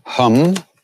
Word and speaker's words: hum: Om hum. Like I hum a tune. Although in India, that's a short A, ha, a, hum, Hanumate, Namaha.